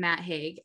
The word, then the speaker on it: Haig: Matt Haig.